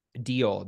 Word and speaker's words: Deol: Say his name again. – Vikram Deal. – Vikram Deal. – Deal. – D-E-O-L.